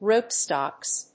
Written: Rootstocks

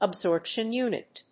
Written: absorption unit

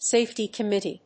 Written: safety committee

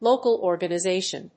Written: local organization

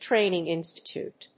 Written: training institute

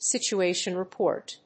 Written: situation report

